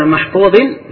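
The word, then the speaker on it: mahfuzin (مَحْفُوظٍ): Ini dibacaan kebanyakan qurra dibaca mahfuzin. Dan Nafi membacanya fi lauhin mahfuzun. Dibaca dengan dhamma.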